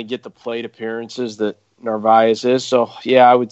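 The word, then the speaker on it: Narváez: Is that how you say it? Narvaez? – Yeah, it's Narvaez.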